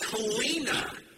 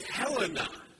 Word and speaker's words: Helena: A lot of people will pronounce it Helena, but the people there call it Helena.